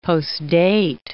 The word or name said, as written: postdate